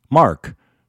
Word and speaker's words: Marc: I mean, I spell my name M-A-R-C, Mark, and I don't get upset when people pronounce it M-A-R-K, Mark.